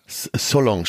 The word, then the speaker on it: Salon: Salon spricht man den.